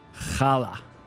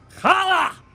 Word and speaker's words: challah: Challah. – All right. Can you do that and yell it? – Like Steve. Challah!